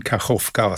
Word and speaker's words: Kachowka: Wie spricht man den richtig aus? – Ich spreche ihn Kachowka aus.